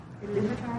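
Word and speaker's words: Ilúvatar: It starts off with Iluvatar, am I saying that right? – Yeah.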